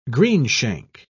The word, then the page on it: greenshank: British English